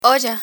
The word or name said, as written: olla